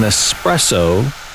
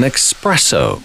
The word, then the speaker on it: espresso: It is an espresso, not an expresso.